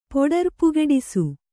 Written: ಪೊಡರ್ಪುಗೆಡಿಸು

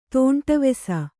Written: ತಾಂಡವಿಸು

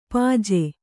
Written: ಪಾಜೆ

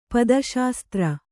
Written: ಪದಶಾಸ್ತ್ರ

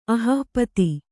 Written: ಅಹಃಪತಿ

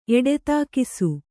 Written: ಎಡೆತಾಕಿಸು

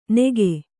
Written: ನೆಗೆ

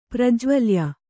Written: ಪ್ರಜ್ವಲ್ಯ

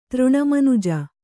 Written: ತೃಣಮನುಜ

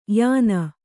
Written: ಯಾನ

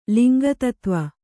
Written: ಲಿಂಗತತ್ವ

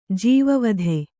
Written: ಜೀವವಧೆ

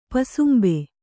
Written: ಪೊಸಂಬ

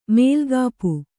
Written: ಮೇಲ್ಗಾಪು

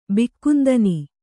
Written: ಬಿಕ್ಕುಂದನಿ

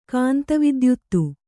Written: ಕಾಂತವಿದ್ಯುತ್ತು

